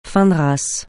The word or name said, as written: fin de race